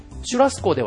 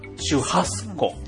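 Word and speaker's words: churrasco: じゃあもしかしてシュラスコも本当はシュハスコ。 – ハスコなんです。はい。 – そうなんですか。 – はい、そうです。 – 日本では結構シュラスコって言っちゃいますけれどもね。 – そうですね。 – シュラスコではじゃあ通じにくい。 – 全然通じますが、うん、正しい発音はシュハ。 – シュハスコ。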